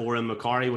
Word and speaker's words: Odhran McCorry: funny spelling name, so I'll spell it at Oran McCorry, which is O-D-H-R-A-N-M-C-C-O-R-R-Y.